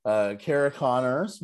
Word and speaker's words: Cara Connors: Our guest this week is the hilarious comedian Cara Connors.